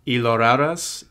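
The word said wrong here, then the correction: llorarás: Something? Is that it? – Lloraras.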